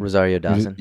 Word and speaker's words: Rosario Dawson: Rosario Dawson.